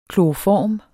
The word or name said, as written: kloroform